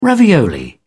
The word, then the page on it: ravioli: British English